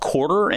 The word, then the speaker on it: Quartr: One that I find myself using more and more recently is an app called Quartr, and it's spelled Q-U-A-R-T-R.